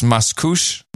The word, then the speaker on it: Mascouche: It's pronounced Mascouche. Yeah, got that. As in de-douche.